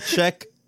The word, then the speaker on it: Czech: Yeah, it's Czech, like checkmate.